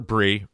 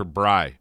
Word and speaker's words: Bry: I called her Bri for many years. I have a theory, which is the actual pronunciation is Bri, but it's been changed to Bree because Bri rhymes with lie, and in the political climate, you can't have a gimme like that.